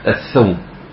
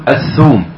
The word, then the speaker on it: الثوم: ها، انا زمان كنت اقراها الثوم. أول واحد صححها لي في خطأي شيخنا عثيمين رحمة الله عليه في درس في الحرم، كان بيتكلم عن التفسير وقواعده، فذكر معرفة ألفاظ اللغة النطق الصحيح لها في التفسير، لأن الثوم مذكور في كتاب الله. خلاص، اللي هو الإيه؟ فومها وعدسها. فقال الشيخ قال الثوم، اقرأ قل الثوم بالضم.